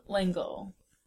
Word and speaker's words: L'Engle: By Madeline L'Engle. – Is that how you pronounce her last name? – Yes.